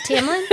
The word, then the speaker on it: Tamlin: Wouldn't mind a tampon redemption arc. – Did you say tampon? – Oh, was it Tamlin? – I'm sorry. – Was that, did I say that incorrectly? – I know.